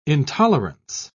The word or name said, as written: intolerance